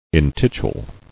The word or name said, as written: Intituling